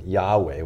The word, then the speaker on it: Yahweh: So from the beginning throughout the Old Testament, God was called, well, we call him Yahweh. We're pretty sure that that's how you pronounce it in the Hebrew.